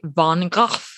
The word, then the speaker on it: van Gogh: Even within this episode, you'll hear his last name pronounced different ways. That's because depending on where you're from, there are various phonetic ways to say the artist's name. In the traditional Dutch, people say van Gogh.